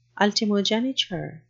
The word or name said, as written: ultimogeniture